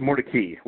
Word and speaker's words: Mordecai: Well, it's actually pronounced Mordecai, which is kind of interesting. – Mordecai. – Yes, that is the Mordecai family. We're not entirely sure when it traditionally it would be pronounced Mordecai. Gets pronounced more, this changes to Mordecai maybe when he marries an Episcopalian.